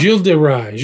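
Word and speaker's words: Gilles de Rais: How do you say his name? Gil de Rais? – Gil de Rais. He's known by different names. Bluebeard, Gil de Rais, Gil de Retz, R-E-T-Z.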